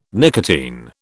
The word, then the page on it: nicotine: British English